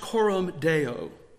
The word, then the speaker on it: coram deo: And then I was thinking how that there is a Latin term that is used and it's pronounced coram deo.